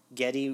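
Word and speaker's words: Gedde: played by I think his name is pronounced Getty Watanabe. Is the actor? – I watched something earlier and heard confirmation that is how you pronounce his first name. – Getty Watanabe, who most of you probably know as Long Duk Dong from 16 Candles.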